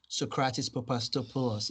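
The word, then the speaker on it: Sokratis Papastathopoulos: What do you think about Socrates Papastopoulos, if I say that right? – Yes, it is right.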